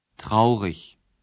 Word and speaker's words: traurig: Certains autres adjectifs portent la marque distinctive, le suffixe IG que l'on prononce ich. – Traurig.